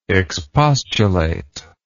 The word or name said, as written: expostulator